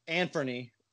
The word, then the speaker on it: Anfernee: First of all, Anfernee is how you say his name. Like, seems like nobody knows how to say it.